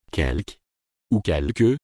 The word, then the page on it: quelque: francais